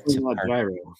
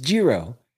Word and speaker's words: Giro: I wear Gyro. – Is it Gyro? We can't say Gyro. It's Giro. – Zero. Yeah. – Okay. They're going to kill us. They're going to kill me. Giro.